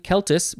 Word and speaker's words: Celt: Next we have celt or celt. Most people say celt. It is capital C-E-L-T, but it looks like it is pronounced celt, and some people say that, and that is okay.